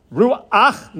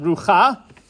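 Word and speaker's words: ruach (רוח): it's not pronounced the way it appears to be written, it's pronounced as if there was an aleph before that last letter, and the aleph has the patach. So just keep in mind, hey, ches, and ayin, ruach, not rucha.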